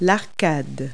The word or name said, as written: arcade